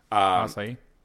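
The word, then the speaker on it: açaí: The acai bowl that I can't pronounce. – Acai.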